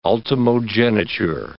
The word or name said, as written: ultimogeniture